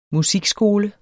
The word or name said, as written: musikskoler